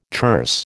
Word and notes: terse: American English

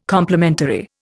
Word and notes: complimentary: Indian

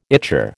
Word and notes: ichor: American English

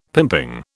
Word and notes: pimping: British English

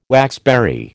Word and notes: waxberry: American English

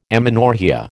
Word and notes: amenorrhoea: American English

